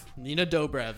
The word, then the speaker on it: Nina Dobrev: Nina Dobrev.